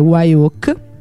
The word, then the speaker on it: Wye Oak: Loro prima si chiamano Monarch e hanno realizzato 3 album in tutto, l'ultimo appunto col nuovo nome, che è Wyoak, si scrive W-Y-E-O-A-K con la K finale.